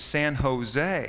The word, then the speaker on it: San Jose: He says, "You know, when you see that J, they pronounce it like an H, so it's not San Jose, it's San Jose."